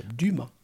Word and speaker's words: Dumas: Inget S på slutet. – Nej, ett Ö också. Dumma.